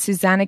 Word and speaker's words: Susana: It's Susanna Kennedy, spelled S-U-S-A-N-A. Everyone spells my name wrong. S-U-S-A-N-A, Kennedy, K-E-N-N-E-D-Y, like the Kennedys, the president.